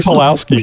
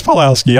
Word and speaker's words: Pulaski: wasn't his name like Mike Pulaski or Pulaski. – Something? – Oh, I think you're right. I think that is Pulaski. I think that is exactly right.